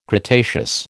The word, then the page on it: cretaceous: American English